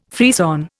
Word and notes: frisson: Indian

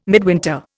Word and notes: midwinter: Indian

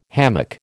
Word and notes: hammock: American English

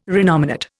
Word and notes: renominate: Indian

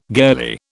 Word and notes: girlie: British English

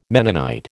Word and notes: Mennonite: American English

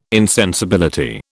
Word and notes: insensibility: British English

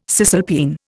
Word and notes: cisalpine: Indian

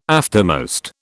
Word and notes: aftermost: British English

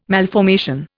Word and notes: malformation: Indian

